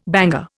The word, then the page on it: banger: Indian